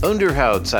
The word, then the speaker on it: Onderhuids: Before that, Elke Klein, Onderhuids. Am I saying that right? Man who speaks Dutch, kind of. Onderhouds, I would say.